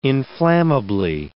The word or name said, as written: inflammably